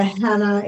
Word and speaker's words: Hannah: So my LinkedIn profile is under Hannah, H-A-N-N-A-H. Family name is Berson, B-E-R-S-O-N.